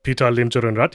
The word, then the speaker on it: Limjaroenrat: Pita Limjaroenrat, yeah, that's correct.